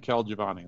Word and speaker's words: Colagiovanni: Well, you pronounce my last name Kel Giovanni, like K-E-L, but nobody knows that.